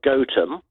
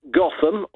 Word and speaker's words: Gotham: They pronounce it Gotham in America. The Americans can never pronounce anything correctly.